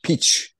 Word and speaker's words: Pitch: E, İsviçreli bir karikatür sanatçısı Pinch Pitch Comment e, P I T C H diye okunuyor yanlış anlaşılmasın.